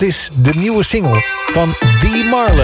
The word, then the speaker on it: The Marloes: Deze bandnaam namelijk T. Marloes. Dus als in wil je nog een kopje thee, Marloes? Maar zo spreek je het natuurlijk niet uit. Nee, het is The Marloes.